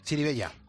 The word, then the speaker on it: Chirivella: ¿Es así? ¿Se pronuncia así? – Chirivella. – Chirivella. Muy bien.